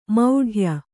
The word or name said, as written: ಮೌಢ್ಯ